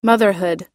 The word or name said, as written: motherhood